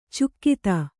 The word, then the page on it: ಚುಕ್ಕಿತ: cukkita